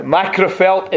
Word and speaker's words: Magherafelt: Macrophel is spelled M-A-G-H-E-R-A-F-E-L-T.